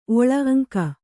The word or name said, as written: ಓಳಿ